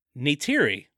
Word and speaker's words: Neytiri: Thanks to the help of Neytiri. Is that how you say her name? – Yeah. – Neytiri, box office queen, Zoe Saldana.